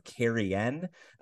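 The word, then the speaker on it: Cairhien: She is from the culture of Carrion. Yes, annoyingly, that is how it is pronounced. The H makes it turn into a word in Sylvan somehow.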